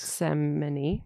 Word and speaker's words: Gethsemane: Gethsemane. Like Yosemite.